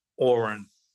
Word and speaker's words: Oron: And his name I have in the book, O-R-O-N. Like, pronounced like Oren.